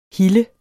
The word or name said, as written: hille